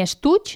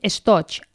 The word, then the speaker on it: estoig: A dins d'aquesta bossa hi porten l'estoig, ni estuig ni plumier. Fixeu-vos que hem dit estoig, amb O.